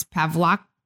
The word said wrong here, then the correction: Paxlovid: Paxlovid. – Paxlovid. There we go.